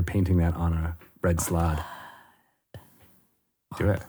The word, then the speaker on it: Slaad: Slad? – Slad. – Red slad. – You got to say it like that.